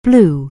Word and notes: blue: anglais

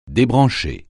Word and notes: débrancher: French

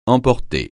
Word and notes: emporter: francais

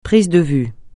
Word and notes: prise de vues: francais

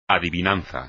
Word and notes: adivinanza: espagnol

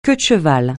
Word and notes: queue-de-cheval: francais

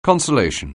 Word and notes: consolation: anglais

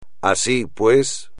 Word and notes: así pues: espagnol

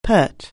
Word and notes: pert: anglais

